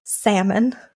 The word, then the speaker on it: Salmon: Okay, so first of all, we must acknowledge that her last name is, in fact, Salmon, like the fish. Yeah. – Like the fish. – Exactly like the fish. And I was afraid the Brits were going to pronounce it like salmon or some shit like that. And they don't. It's salmon. It's pronounced correctly.